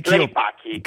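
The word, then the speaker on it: Clay Paky: Mi corregga se sbaglio la pronuncia, forse Clay Paki S.P.A. Sì. Buonasera. – Buonasera, buonasera anche a chi ci ascolta. – Com'è? Clay Paki? – Clay Paki. – Clay Paki, molto sì.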